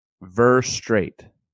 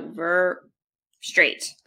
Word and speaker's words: Verougstraete: Verougstraete. Yeah. So Verougstraete, like a straight line.